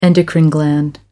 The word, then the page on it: endocrine gland: American English